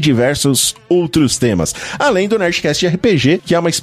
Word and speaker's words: Nerdcast: Como é assim que pronuncia? – Nerdcast.